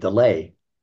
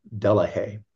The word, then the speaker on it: Delahaye: But in France or in Belgium, part where they speak French, they pronounce Delahaye Delay, but the English pronunciation of it is Delahaye.